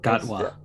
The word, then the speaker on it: Gatwa: Oh yeah, the thing about the Gutwee's role, am I saying that right? – Gatwa. – Gatwa, thank you.